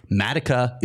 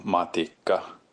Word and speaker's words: Matikka: And I, you know, we were saying Matika, that's not right. Matika is not right either. Both of those are wrong. I'm hearing broadcasts saying Matika, that's not correct. Here, thank you, Timo Seppä, I should say, is the proper pronunciation of the name. – Mikko Matikka. Mikko Matikka.